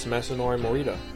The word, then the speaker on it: Masanori Morita: But it's yes, Masanori Morita. you said it right.